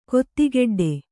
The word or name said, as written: ಕೊತ್ತಿಗಡ್ಡೆ